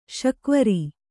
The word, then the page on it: ಶಾಕ್ವರ: śakvara